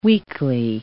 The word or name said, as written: weakly